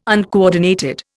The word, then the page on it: uncoordinated: Indian